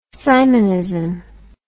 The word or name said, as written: simonism